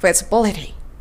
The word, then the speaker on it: feasibility: feasibility の b と liver の e は同じショートイ」と呼ばれる曖昧な e になります。 liver, feasibility というふうにちょっとぼやっとさせて発音するといいですね。